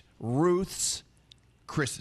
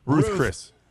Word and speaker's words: Ruth's Chris: It's Ruth's Chris Steakhouse. – Because they call it Ruth Chris. – Ruth's. Yeah. It's Ruth's, not Ruth Chris.